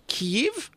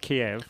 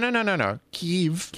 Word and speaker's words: Kyiv: Now, Eurovision will be apparently in Kyiv. I think that's how you say it. – Not Kiev. – No. Kyiv.